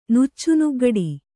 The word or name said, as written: ನುಚ್ಚುನುಗ್ಗಡಿ